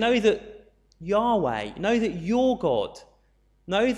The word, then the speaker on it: Yahweh: It's the four letters YHWH, and we tend to pronounce that Yahweh, but you'll very rarely see that written in the Bible because it's normally translated as Lord in small capitals.